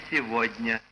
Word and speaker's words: сегодня: Отсюда и слово «сегодня» — «г» произносится за «в».